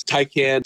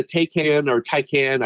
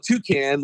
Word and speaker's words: Taycan: It was a year ago or so, I think, that there was a Taycan, I'm really not up on my EV pronunciation. I always want to call it Toucan, like Toucan Sam, but I do that.